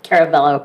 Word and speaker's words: Carabello: Maura Carabello, did I say that right? – Yeah, Carabello, but Carabello. – No, close enough.